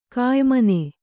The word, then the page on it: ಕೆಯಿಮನೆ: Kannada